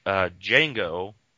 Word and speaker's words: Django: And then there was Django, where the D is silent.